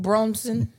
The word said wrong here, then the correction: Brunson: Listen. – Brunson. – Brunson does sound better than Braun.